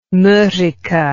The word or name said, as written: morike